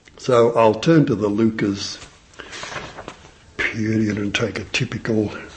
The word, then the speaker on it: Leuchars: a place which you have to give a slight cough to pronounce, Lucas. Between Dundee and St Andrews. – Just spell it for you. – L-E-U-C-H-A-R-S.